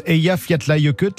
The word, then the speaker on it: Eyjafjallajökull: Ah, ça se prononce Eyjafjallajökull.